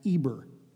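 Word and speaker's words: Eber (עבר): The actual word Hebrew, it contains the letters Ayin, Bet, Resh, and it's pronounced Eber.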